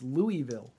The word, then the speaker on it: Louisville: Nobody agrees, but I know how not to say it. And that's Louisville. I know that's not the right way. It's Louisville.